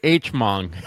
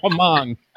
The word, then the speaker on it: Hmong: I tried all day to make sure I didn't say Hmong or Hmong. I tried all day to remind myself that H is silent.